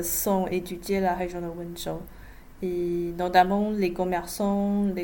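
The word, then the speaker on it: Wenzhou: Alors vous vous êtes surtout concentré sur l'étude de personnes originaires de Wenchu, on prononce comme ça? – Wenchu, oui.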